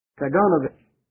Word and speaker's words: Kaganovich: Now, Kaganovich is spelled K-A-G-A-N, and the Ovich is a Russian suffix added to the name of a ruler. Kaganovich.